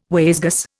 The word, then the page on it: wayzgoose: Indian